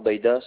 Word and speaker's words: Bahida: Alors, mon nom de famille, c'est Amar Baida, ça s'écrit A-M-A-R. – Oui. – Plus loin, B-A-H-I-D-A.